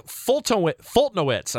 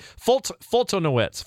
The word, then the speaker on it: Foltynewicz: Mike Foltynewicz, I believe is how you say it. Mike Foltynewicz, F-O-L-T-Y. N-E-W-I-C-Z. Whatever the hell the guy's name is pitching on Saturday. Mike Foltynewicz. I think Foltynewicz.